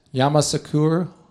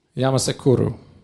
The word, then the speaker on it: Yamoussoukro: If anyone else knows how to better pronounce it. You know how to pronounce it. Yamoussoukro. Yamoussoukro. Okay.